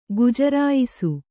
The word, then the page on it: ಗುಜರಾಯಿಸು: Kannada